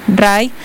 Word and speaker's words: Rhye: Y otra banda que esta mañana anunció, ahorita que estamos hablando de música, de conciertos, por primera vez en Quito también, Rye o Rai, ¿cómo se escribe? R-H-Y-E, Rai.